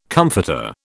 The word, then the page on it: comforter: British English